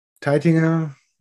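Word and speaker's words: Taittinger: Das hat sich dann positiv entwickelt und ähm, ja, Teitinger ist einer der Namen, die ein bisschen deutscher klingen wie Moe Chandon oder Clicot.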